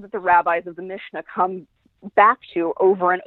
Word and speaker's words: Mishnah: So in the piece in the piece, you also talk about something called Mishnah. Did I say that correctly? – The Mishnah, yes.